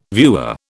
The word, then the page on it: viewer: British English